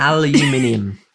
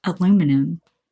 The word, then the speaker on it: aluminium: Aluminium. – Aluminum. – No, because it's got more letters than that.